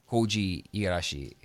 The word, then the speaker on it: Koji Igarashi: Koji Igarashi, he made nice pronunciation, I guess.